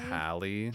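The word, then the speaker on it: Hailee: Holly? Holly? The girl. – Oh, Hallie. – Hallie? – That's it. – Hallie. – That's how people pronounce Haley's name.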